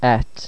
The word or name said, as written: ait